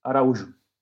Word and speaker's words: Araujo: That's actually a common mispronunciation because you guys pronounce it like it if it were Spanish. So yeah. – In Spanish, that's probably right. But in Portuguese, it's Rodrigo Araujo.